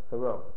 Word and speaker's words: Thoreau: Thoreau.